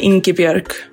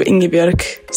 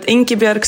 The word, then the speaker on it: Ingibjörg: I mean, with an Icelandic accent, you would say Ingibjörg. But with your more singing Swedish accent, it sounds good too, Ingibjörg. So, but Ingibjörg Sigurðardóttir, that's how you say it.